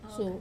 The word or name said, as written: su